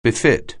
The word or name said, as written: befit